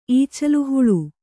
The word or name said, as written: ಈಚಲುಹುಳು